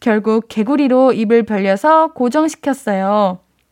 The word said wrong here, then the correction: 개구기: 옛날에 라미네이트 하다가 잠든 적이 있는데, 아,개구기를 개구리라고 읽었네요. 죄송해요. 우리 볼륨 가축도 다 아시잖아요. 개구기, 에, 개구기. 그거 이 벌리게 하는 거.